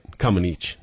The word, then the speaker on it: Comăneci: And I know you must remember it because you said her name right. Comăneci, not Comăneci.